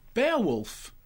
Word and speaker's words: Beowulf: Beowulf. – Beowulf. – Like bear... Wolf.